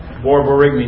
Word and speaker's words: borborygmi: The up would be borborygmi. Now, Boards loves the word borborygmi. B-O-R-B-O-R-Y-G-M-I.